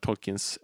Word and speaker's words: Tolkien: som hade varit Tolkiens eh, arbetskamrat. – Mm. – Och han sa ju Tolkien med mm. tydlig, jättelång stavelse och betoningen på andra stavelsen.